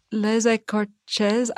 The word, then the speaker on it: Les Écorchés: Les Écorchés.